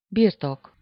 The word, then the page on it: birtok: /ˈbirtok/